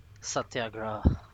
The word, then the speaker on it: Satyagraha: Satyagraha.